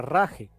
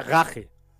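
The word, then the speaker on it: Rache: la palabra raje. – ¿Así se pronuncia? – Ok. No, se pronuncia gaje.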